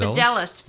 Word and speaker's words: Fideles: They're with Fideles. Did I butcher that one? That Fideles. – One's good. Fideles.